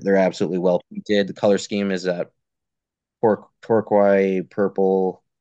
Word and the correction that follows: turquoise: How do you say turquoise? – Turquoise purple.